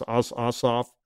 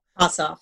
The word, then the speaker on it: Ossoff: Then there's a regular old Senate race with Senator Perdue running for re-election, and I can never pronounce his name. It's Ossoff. – Ossoff, yeah.